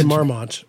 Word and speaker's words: Marmont: I'm American, so I say Marmont. – You nailed it. Chateau Marmont. – But I think in the intro, I was like, Chateau Marmont. I didn't know what to say. – That's Marmont.